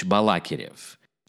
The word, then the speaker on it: Balakirev: So we speak of Mily Alexeyevich Balakirev. I specifically stress Balakirev and not Balakirev.